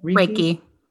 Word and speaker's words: Reiki: So I imagine, like, any type of journaling or meditating or yoga or breath work or Reiki, is that how you say it? – Reiki.